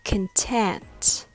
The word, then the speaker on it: content: Content has the stress on the second syllable, so the first syllable is reduced. It becomes smaller and weaker, like can, can, content. Other words like computer, control, compare, all has the first syllable reduced. So it's not pronounced as con it becomes kin.